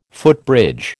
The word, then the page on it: footbridge: American English